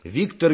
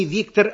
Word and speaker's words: Виктор: Ну, писатель знаменитый такой, Виктор Гюго. – Ага. Во-первых, не Виктор, а Виктор. Во французском языке ударение на последнем слоге.